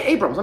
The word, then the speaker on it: Abrams: Yeah, Abrams.